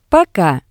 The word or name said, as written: Poka